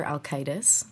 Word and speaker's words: Alkaitis: The first one is called Dr. Alkitis, mm-hmm. which is spelled A-L-K-A-I-T-I-S.